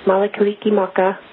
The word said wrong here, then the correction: Mele Kalikimaka: And yes, mele kalikimaka to you.